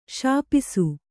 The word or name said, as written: ಶಪಿಸು